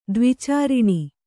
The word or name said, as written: ದ್ವಿಚಾರಿಣಿ